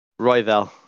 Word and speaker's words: Royval: Moreno versus Royval. Or Royval. I can never decide which way they pronounce that one. – Royval. I'll just sell it for Royval. Yeah. – There you go. – Yeah. Yeah. I believe that's the Anik pronunciation there.